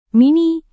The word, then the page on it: mini: Indonesian